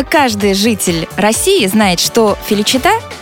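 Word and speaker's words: Felicità: Вы знаете, мне повезло, и в своей жизни я исполнил три композиции, которые, ударение в которые заканчиваются на «а», и ударение падает на эту последнюю «а». Это «Феличита», «Счастье», «Либерта», «Свобода» и «Чисара», «Будущее».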